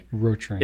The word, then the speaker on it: Rotring: But well, anyway, yeah, Rotring is closer to the German pronunciation than okay. Rotring is.